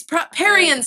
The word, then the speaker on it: Parian: Do you also read it Parian? – I also read it Parian. Yeah, it's Parian sounds right.